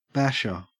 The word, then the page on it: basher: Australian English